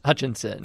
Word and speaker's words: Hutchinson: I'm doubling down on the Asad Hutchins. Hutchins, am I saying it right? – Say it one more time. – Asad Hutchins. – No, you mispronounced it. Hutchinson.